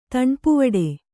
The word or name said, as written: ತಣ್ಪುವಡೆ